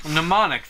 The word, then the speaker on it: Mnemonic: Mnemonic. The M is silent.